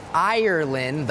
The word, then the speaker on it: Ireland: ค่ะว่าทริกกี้ยังไง So when you're listening to the word Ireland the R is important Some people might hear Island or even Iceland